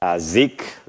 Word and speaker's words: Zik: So for those of you who have met me, I typically prefer Zik, Z-I-K, or Z-I-K, depending on how you pronounce or how you say the Z or Z.